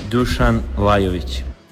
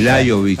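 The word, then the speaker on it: Dušan Lajović: Dusan Lajovic. – Dusan Lajovic. – Exactamente. – Lajovic. Aparece como una I. La J como I.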